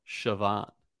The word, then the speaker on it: Siobhan: My coworker actually has a daughter named Siobhan, which is spelled, get this, Siobhan, pronounced Siobhan. Which looks, yeah, basically that's Gaelic too.